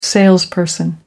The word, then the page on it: salesperson: American English